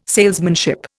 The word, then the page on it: salesmanship: Indian